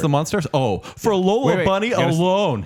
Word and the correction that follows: Monstars: You got to say Monstars. – Monstars.